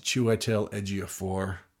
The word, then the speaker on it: Chiwetel Ejiofor: I even looked up how to say it, Ch Chiwetel Ejiofor.